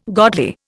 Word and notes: godly: Indian

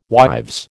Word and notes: wives: American English

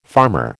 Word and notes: farmer: American English

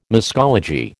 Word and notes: muscology: American English

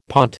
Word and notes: pont: American English

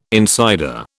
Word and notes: insider: British English

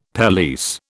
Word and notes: pelisse: British English

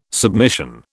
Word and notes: submission: British English

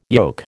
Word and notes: yoke: American English

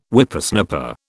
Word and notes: Whippersnapper: British English

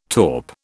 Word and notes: taupe: British English

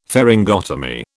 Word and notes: pharyngotomy: British English

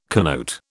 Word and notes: connote: British English

